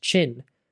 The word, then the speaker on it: Qin: The state of Qin, pronounced Chin, was victorious, and China takes its name today from the Qin dynasty.